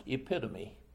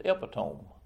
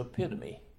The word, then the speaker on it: epitome: After the sermon was over, she came up to me and said, "That word is epitome."